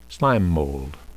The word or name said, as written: slime mold